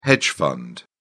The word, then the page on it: hedge fund: British English